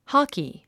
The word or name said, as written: hockey